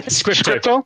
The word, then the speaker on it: Scrypto: So what makes Scrypto or Scrypto, how do you pronounce it? – Scrypto. Yes, Scrypto.